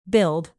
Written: billed

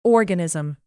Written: organism

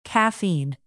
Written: caffeine

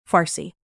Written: farsi

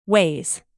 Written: weighs

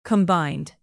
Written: combined

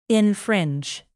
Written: infringe

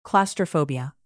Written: claustrophobia

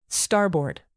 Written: starboard

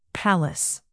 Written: palace

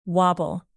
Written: wobble

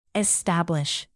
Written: establish

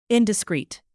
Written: indiscreet

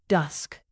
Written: dusk

